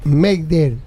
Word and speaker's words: Mayday: Que cuando los franceses tenían una emergencia utilizaban la palabra Mayday, que significa Mayday, que significa ayúdame, que se escribe M-Aider. Mayday se pronuncia, Mayday.